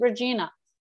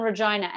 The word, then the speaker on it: Regina: They could pronounce it Regina. Mm-hmm. They could. It's spelled the same. – Right. – But they insist on Regina. And if you go to Regina, Saskatchewan in Canada, and you make the joke, which you have to make. – Yep. – That it rhymes with vagina.